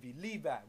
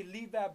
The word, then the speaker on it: Levi: I'm reminded of Levi. We say Levi, but I believe it's pronounced Levi.